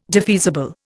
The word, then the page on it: defeasible: Indian